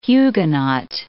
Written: Huguenot

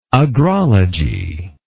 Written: agrological

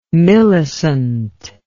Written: millicent

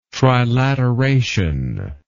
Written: trilateration

